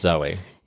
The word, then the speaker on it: Zooey: Is it Zooey or Zoe? Anyway, Zooey Deschanel. Well, it's spelled Z-O-O-E-Y. – It's just Zoe.